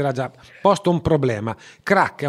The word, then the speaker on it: Crack: È della redazione di una rivista nuova, nuovissima, che vede l'aria, vede la luce oggi e si chiama Rivista Crack. Ho detto bene? – Sì, esattamente.